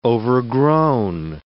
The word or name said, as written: overgrown